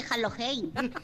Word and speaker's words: Halloween: No sé quién eres, pero me parece que no se dice así, se dice Halloween.